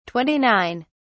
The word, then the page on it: 29: British English